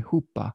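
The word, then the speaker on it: chuppah: I think I'm saying that correctly, huppah. It's C-H-U-P-P-A-H, which was a bridal structure for a wedding ceremony.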